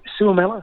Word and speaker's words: Suomela: I think Suomela would be the young man who goes, anti Suomela, is that how you say it? – I believe so.